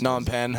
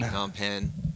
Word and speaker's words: Phnom Penh: I can't even pronounce that name, Cambodian place. – Phnom Penh. – Phnom Penh.